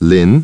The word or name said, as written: Lin